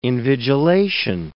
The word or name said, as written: invigilation